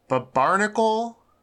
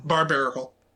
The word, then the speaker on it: Barbaracle: But even more so than Pelipper, Barnacle? Is that how you say it? – Bionicle. – What's the second one? What's the evolve form? – Barbaracle.